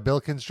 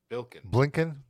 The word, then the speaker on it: Blinken: A little bit in Bilkin's drink. They could all get together and chill. – Lincoln? – Blinken.